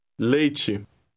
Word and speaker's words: leite: The EI combination is pronounced A. – Read word 13. – Leite.